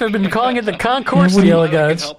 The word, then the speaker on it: Concours: Is the S silent? Is it Concours or Concourse? – No, Concours. We say is in the airport. – Okay. – The airport Concourse. – Okay. – Or you pronounce it Concours is a French word. – Oh, so these you do not pronounce the S.